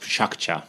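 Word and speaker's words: PechaKucha: Well, it's technically pronounced Pshakcha, because it's a Japanese word and it means the sound of chit-chat.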